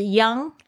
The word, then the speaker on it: young: Feel, F-E-E-L, 就是感觉，后面可以直接加形容词。我们今天加的两个形容词是 young and vibrant. Young, Y-O-U-N-G, 这边呢要注意一下那个 NG 的发音哦，它是这个后舌，就是你舌头的根部翘起。